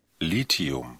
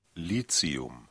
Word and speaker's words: Lithium: Heißt es jetzt eigentlich Lithium oder Lithium? Wir haben da die sogenannte Aussprachedatenbank zum Nachhören und das klingt dann so. – Lithium. – Ah, oder auch so. – Lithium. – Geht also beides.